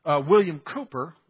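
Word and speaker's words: William Cowper: For a season, William Cooper, and that's how you pronounce that, William Cooper also, he wrote, I think, a couple hundred of them, of the many hundreds that were written.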